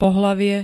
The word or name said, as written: pohlavie